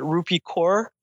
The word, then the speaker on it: Rupi Kaur: I read this beautiful quote by, and God help me if I say her name wrong, but Rupi Kaur. – Yes, hopefully I said that right. – You did. You got it correct.